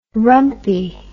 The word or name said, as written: rumpy